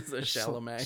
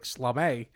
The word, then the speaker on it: Chalamet: Chalamet. – Chalamet? I keep saying it like I'm Jewish, like Schlamet.